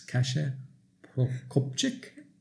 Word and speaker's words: Kasia Prokopczyk: Welcome to the Speaking Podcast, and today my guest is Kasia Prokopczyk. Did I say it right? – Yes, that's right.